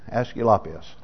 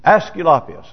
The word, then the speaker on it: Aesculapius: His name was Aesculapius. Aesculapius. Aesculapius, that's how I would pronounce it.